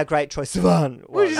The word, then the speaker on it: Sivan: Sivan.